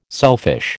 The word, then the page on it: selfish: American English